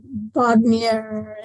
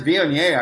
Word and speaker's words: Viognier: So for those who don't know, Viognier is a hard wine to pronounce, and it's V-I-O-G-N-I-E-R, Viognier. And people pronounce it all different ways, like Bognier and all sorts of things.